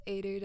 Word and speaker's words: Eadair: It's Eadair. You better pronounce it right because people be getting it wrong.